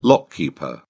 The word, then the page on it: lock keeper: British English